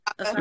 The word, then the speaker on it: Osaka: the other thing about the Sports Illustrated uh, cover that is really interesting is the excuse me the tennis girl Naomi Osaka. Osaka. Am I saying that right? Mm-hmm. Osaka. Mm-hmm. Okay. Like that.